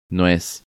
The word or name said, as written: Nuez